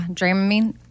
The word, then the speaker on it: Dramamine: Is it Dramamine? Is that how you say it? – Yeah, Dramamine.